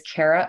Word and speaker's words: Kara: I took a long hiatus during the pandemic, but my Instagram, all one word, is Kara Ocean. My name is spelled with a K, Kara Ocean.